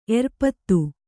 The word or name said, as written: ಎರ್ಪತ್ತು